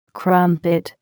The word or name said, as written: crumpet